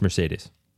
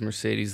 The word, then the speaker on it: Mercedes: Which, frankly, the only reason Nick says Mercedes the way he does is because I told him Mercedes has three E's and they're all pronounced differently.